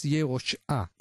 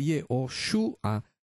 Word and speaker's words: Yehoshua: que en hebreo es Yehoshua, hijo de Nun. Entonces yo lo pronuncié como Yehoshua, hijo de Nun. Pero si yo voy y leo según la pronunciación de los masoretas, de cómo ellos decidieron que se va a pronunciar este nombre, entonces tendría que leer Yehoshua, porque los masoretas le agregaron una U.